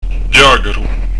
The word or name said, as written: ghjacaru